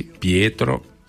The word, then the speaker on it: Pietro: Además depende mucho del, en el caso italiano, depende mucho de la región. Porque hay regiones, según la región, la pronunciación de los nombres es distinta. Uh-huh. Entonces, bueno, en mi caso yo soy de Liguria y se pronuncia así, Pietro.